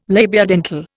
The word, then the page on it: labiodental: Indian